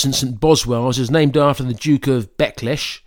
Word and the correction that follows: Buccleuch: Actually, it's pronounced Buccleuch, the Duke of Buccleuch.